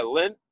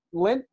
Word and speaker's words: Lindt: Am I pronouncing it correctly? – Yeah, Lindt. – Yeah, Lindt, L-I-N-D-T. I don't know. – Yeah, like in your belly button, but with a D.